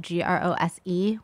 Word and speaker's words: Grose: And my last name is spelled Grose, which is a little weird.